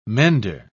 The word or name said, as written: mender